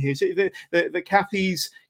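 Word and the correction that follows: Cathays: Community Centre in Cardiff. – Cathay's.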